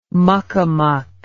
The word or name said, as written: muckamuck